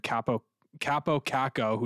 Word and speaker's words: Kaapo Kakko: Like, you have Alexis Lafreniere, who was consistently playing like third line hockey a lot this year, and Kaapo Kakko, whose name I always struggle with for some reason. It's four syllables, you fucking dummy.